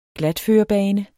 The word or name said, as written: glatførebane